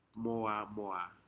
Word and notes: moa moa: Aneityum

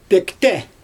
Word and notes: pööktE: Xyzyl

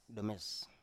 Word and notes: nomes: Biak

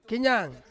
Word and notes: kenyang: Ansus

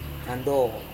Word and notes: nando: Serewen

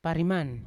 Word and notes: pariman: Yawa

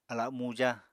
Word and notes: ala muja: apatani

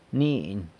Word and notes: mniny: sanlucasquiavini

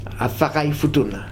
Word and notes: afakae: Futuna

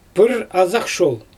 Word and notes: prrazaxshol: Xyzyl

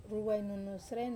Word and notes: ruwainunu sren: wamesa

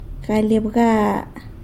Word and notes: galy abga: sanlucasquiavini

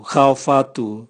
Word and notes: gaovatu: Raga

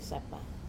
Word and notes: sapa: Wamesa

